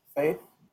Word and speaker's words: Fejth: because apparently in Sweden, that spelling is how you would pronounce Faith, F-E-J-T-H, and it just stuck since, so, and I like it.